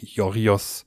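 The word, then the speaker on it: Yorgos: im Griechischen wird das G ja, glaube ich, wie ein J ausgesprochen. Jorios, Jorios äh, Lanthimos oder Lantimos.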